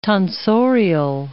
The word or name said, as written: tonsorial